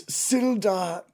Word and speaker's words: Sildar: First of all, did you say his name is Dildar? – Oh, sorry. Please continue. – My name is Sildar, not Dildar.